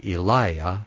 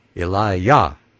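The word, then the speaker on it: Elijah: And I'm putting the accent on the Yah in Eliah, even though in the King James it says to put it on the second syllable, like Eliah. It should actually be Eliah, to emphasize that our Father's name is in our name too.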